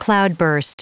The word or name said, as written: cloudburst